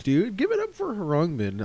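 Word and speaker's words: Khruangbin: And this next track is full credit to Harangbin, which is how you pronounce it.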